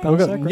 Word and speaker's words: Palascak: Am I pronouncing that right, Palascak? – Yeah, perfect.